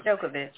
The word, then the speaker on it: Djokovic: I can't pronounce Djokovic. – Djokovic is being held captive in the airport,